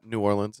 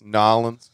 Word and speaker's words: New Orleans: It's not New Orleans. It's Nolens.